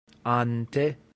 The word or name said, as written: ante